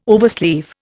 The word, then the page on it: oversleeve: Indian